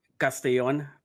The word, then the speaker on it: Castellon: It's it's it's pronounced Castellon, but Cas- Castellon.